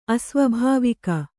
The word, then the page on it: ಅಸ್ವಾಭಾವಿಕ: asvābhāvika